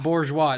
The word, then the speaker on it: bourgeois: Bourgeois, it's French. – Fuck, I was way off. – And Borges. – Borges. – Bourgeois is an adjective.